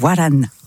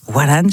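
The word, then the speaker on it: Wahran: Khaled, on l'écoute avec Waran. Waran, je prononce mal. Vas-y. – Tu sais, t'es vraiment très proche. Waran, qui veut dire Oran.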